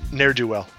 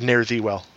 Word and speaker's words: ne'er-do-well: Ne'er-do-well. – Ne'er-do-well. Did I say it wrong? – Yeah, you said ne'er thee well. – Ne'er thee well. – Ne'er do. – Ne'er do. – Never do well.